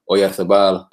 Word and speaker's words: Oyarzabal: Oyarzabal playing.